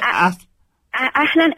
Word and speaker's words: Ahlan: What is it? – Ahlan, A-L. – Ahlan. – Ahlan, A-H. – A-H. – L-A. – L-A. – E-N. – Alan. – No, no, Ahlan. A-A-H. A-H-L. – A-H-L-A.